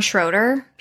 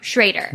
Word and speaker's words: Schrader: Do you pronounce his name John Schroeder? – Schrader. Which okay. We learned. Yeah, we pronounced it Schroeder for so long.